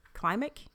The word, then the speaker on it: Klimek: And K-L-I-M-E-K. Klimek? Klimek. I believe that's how you say it.